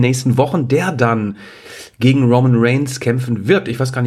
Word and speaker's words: Roman Reigns: Komisch betont, Roman Reigns. Mhm. So betont man den ja gar nicht. Roman Reigns heißt das.